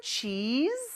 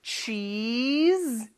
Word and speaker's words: cheese: Do you want some cheese? 네, 치즈가 아니고 cheese. 이렇게 좀 길게 이를 끌어줘야 됩니다.